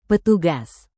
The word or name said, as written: petugas